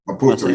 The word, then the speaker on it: Maputo: The town is Maputo? – Maputo, yeah.